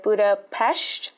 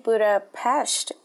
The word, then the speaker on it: Budapest: I'm saying Budapest because that's actually how you say what we Americans might call Budapest. Because in the Hungarian language, you don't pronounce an S as unless it's next to a letter that they call Z. So if the S is next to a Z, then it would be pronounced Budapest. But because there's no Z in Budapest, you actually pronounce it Budapest.